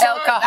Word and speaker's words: El Cajon: How do you pronounce it? – That's El Cajon. That's right.